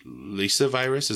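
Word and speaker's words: Lyssavirus: Lyssa virus. Is that how you pronounce that? – Yeah.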